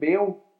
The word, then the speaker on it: Baal: And generally, now if I get the Baal, is it Baal? Is that how you pronounce it? Nailed it.